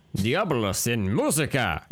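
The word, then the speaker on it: Diabolus in Musica: You go ahead and pronounce it. – Diabolos in Musica. – Okay, there we go.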